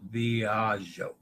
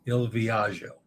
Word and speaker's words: Il Viaggio: All right, Dennis says, Viaggio. Il Viaggio. Don't copy the cruise clowns who mispronounce GGI as Z, okay? Il Viaggio.